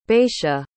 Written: bacia

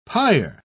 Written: pyre

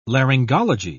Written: laryngology

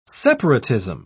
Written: separatism

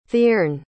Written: theirn

